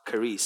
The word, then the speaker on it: charis: We want to take off from looking at what grace is, on what the word grace, charis, means. It's actually spelled charis, but it's pronounced charis, C-H-A-R-I-S. Some people spell it phonetically, so K-A-R-I-S. They spell it how it is pronounced.